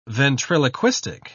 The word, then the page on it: ventriloquistic: British English